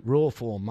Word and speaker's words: Rawform: How do you pronounce that? Raw form? – R-A-W, raw form, mate. R-A-W-F-O-R-M.